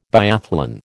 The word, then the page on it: biathlon: American English